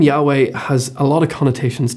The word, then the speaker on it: Yahweh: the two syllables Yahweh, sound almost onomatopoeic for breath. That inhale of Yah and the exhale of Hoy.